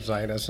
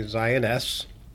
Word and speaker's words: Zioness: Uh, I pronounced it Zionist until I heard you say Zioness. It is called it's like Zioness, The Lioness.